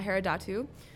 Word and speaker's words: Herodotou: Yes, Katarina Herodotou. I don't think I said that right, but I'm not Greek, so I can't help it. Did I say it right? – You did all right.